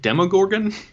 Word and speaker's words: Demogorgon: How do you even pronounce that? Demigod? – I think it's Demogorgon.